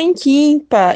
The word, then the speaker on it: Nkinpa: Então, Inquimpa se escreve assim, ó, NKINPA, dá até um rap, gente, NKINPA, Inquimpa.